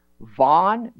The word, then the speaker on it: Vaughn: Vaughn, V-A-U-G-H-N, Vaughn. And sometimes Vaughn is spelled V-A-U-G-H-A-N.